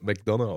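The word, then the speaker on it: McDonagh: sein McDonough, der wird so eigentlich McDonough äh, wird der äh, geschrieben D O N A G H, glaube ich, also wird McDonough ausgesprochen.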